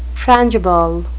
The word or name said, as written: frangible